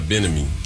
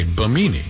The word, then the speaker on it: Bimini: It's Bimini. Bimini. – Bimini. – Oh, Bimini. Not, okay. – Not Benami. – Okay, Bimini.